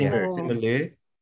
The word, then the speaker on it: Simu Liu: Simu Liu? Is that how you pronounce it? – Simu Liu. Yeah.